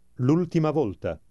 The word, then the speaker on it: L'ultima volta: Notice that the first word, with the apostrophe, is pronounced just as if it were all one word. Read it aloud again. – L'ultima volta.